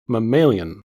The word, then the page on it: mammalian: American English